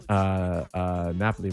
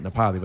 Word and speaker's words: Napoli: The idea about like now I look for Ferraris and Salvador Dali's, like he can't pronounce Napoli, right? He pronounces it Nepali.